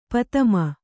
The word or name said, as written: ಪತಮ